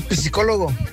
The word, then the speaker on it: psicólogo: Tengo cita con el psicólogo. Y yo le dice la secretaria: señor, la P no se pronuncia.